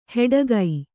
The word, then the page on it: ಹೆಡಗೈ: heḍagai